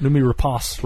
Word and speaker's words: Noomi Rapace: Did I pronounce her name correctly, Chris, by the way? Is it Noomi Rapace? Rapace, maybe? – No, I believe you're correct.